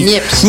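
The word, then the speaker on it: Niépce: Pas dire Nieps. – Ça s'écrit N-I, accent grave, P-C-E. Voilà. C'est Nieps. Nieps, voilà.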